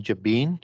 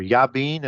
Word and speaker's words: Jabin: Then the northern campaign with like King Jabin, or Yabin as it's probably pronounced, King of Kings, and that great northern alliance.